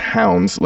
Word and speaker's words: Hounds: That's, I say it like that because people always think I'm saying Ghost Town, but it's Hounds, like the dog. – Yes. – Like a hound.